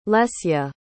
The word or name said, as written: lesia